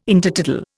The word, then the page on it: Intertidal: Indian